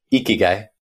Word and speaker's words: ikigai: the Japanese concept of ikigai. And I don't know if I pronounced that right. – Yeah, ikigai.